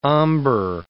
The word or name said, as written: ombre